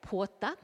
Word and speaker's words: pwoja: Pedro Wonaeamirri is the use of what's called a porta, and it's spelt P W O J A. Once again, the linguists aren't very helpful, it doesn't really help us say it. I believe you say it porta,